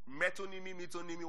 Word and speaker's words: metonymy: Metonymy, what's it pronounced? Metonymy. Is that right? It's not metonymy. What's it pronounced? Metonymy. Okay, me. Metonymy. Yeah? Metonymy, right? My wife has corrected me on that a lot of times.